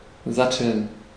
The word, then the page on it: saddle: [sæ.dəl]; /ˈsædl̩/